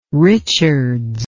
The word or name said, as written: richards